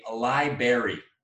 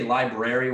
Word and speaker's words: library: But when they say library, when they don't say library, when it's like, we got to go to the library. It's not a berry.